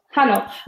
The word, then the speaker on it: Hanok: Can you tell me a bit about the Hanok? Am I pronouncing that correctly? The Hanok review. Hanok. – Hanok. Yeah. Hanok.